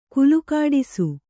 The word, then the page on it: ಕಾಲ್ಗಿಡಿಸು: kālgiḍisu